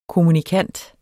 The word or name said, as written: kommunikant